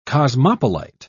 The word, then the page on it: cosmopolite: British English